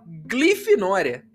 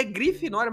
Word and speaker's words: Grifinória: Inclusive, não é a primeira vez, ele me alertou lá atrás por mensagem que eu falava glifinória. E ele tá certo, eu falava glifinória e é grifinória, mas eu sempre falei com L.